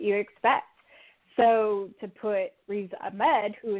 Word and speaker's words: Ahmed: Amen. – Riz Ahmed.